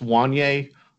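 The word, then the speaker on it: Wanya: Just to prove Sean's point, it is pronounced Wanye. On his current Tennessee Vols profile, it says Wanye, and in parentheses, rhymes with Kanye.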